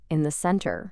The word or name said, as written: in the centre